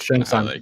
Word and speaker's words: Shang Tsung: The Emperor's sorcerer, Shang Tsung, I hope I'm pronouncing it right. I don't know. – Correct. Shang Tsung. Yep. You got it.